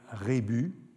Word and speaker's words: rébus: N'est-il pas plus exact de dire rébus, en tout cas au singulier, un rébus, des rébus, comme dans le Midi, et même si le mot vient d'un ablatif latin.